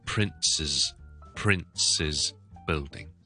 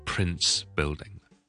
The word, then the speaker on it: Prince's Building: 就会读翻咧 Prince's Building，就 Prince's Building 太子大厦，就唔系就咁 Prince Building。